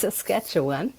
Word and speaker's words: Saskatchewan: Also, der heißt Saskatchewan.